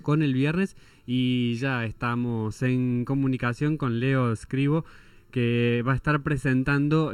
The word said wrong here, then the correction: Leo Cribó: Leo Cribó, perdón, lo pronuncié mal.